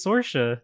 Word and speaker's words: Saoirse: Also, every time I see Saoirse Ronan's name, I lose my shit because her name's like spelled like Sourcy or something. – Yeah. Mm-hmm. – But you say it's Saoirse.